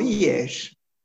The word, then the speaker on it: Liège: It's called Liège. It's a city east of Brussels. – Oh, Liège? How do you spell that? – L-I-E-G-E.